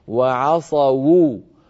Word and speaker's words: وعصوا: وعصوا الرسول، الواو هنا مضمومة والواو تحتاج إلى ضم شفتين والضمة تحتاج إلى ضم شفتين، فكيف إذا كانت الواو مضمومة؟ فيكون ضم الشفتين آكد فيكون نطقها هكذا وعصوا وو، فننطق الواو ونضمها وعصوا الرسول.